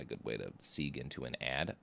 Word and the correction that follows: segue: Also, Sieg isn't the right way to say segue.